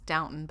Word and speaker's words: Downton: Well, I'm pretty sure it's pronounced Downton, but it's spelled downtown. – It's D-O-W-N-T-O-N.